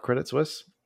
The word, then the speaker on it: Credit Suisse: Thanks, Coil Spotting it, it, if you pretend you're French and you have a cigarette in your hand, it's Credit Suisse.